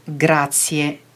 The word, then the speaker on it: grazie: Je schrijft G-R-A-Z-I-E. Maar in het Italiaans spreek je de I en de E niet als een I uit zoals in het Nederlands, maar het blijven twee losse klinkers. Dus je zegt grazie.